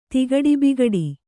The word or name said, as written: ತಿಗಡಿಬಿಗಡಿ